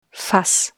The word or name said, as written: Fass